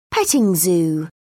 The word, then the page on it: petting zoo: British English